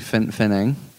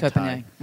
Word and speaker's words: Phangan: Are you saying Koh Phangan correct, by the way? – No, it's annoying me that he keeps saying Phanang. – How do you say it? – Phanang. – Even Pad Thai is actually correctly spelled PH. – Koh Phangan. Okay.